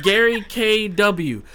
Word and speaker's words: Gary K.W.: It's just Gary K.W.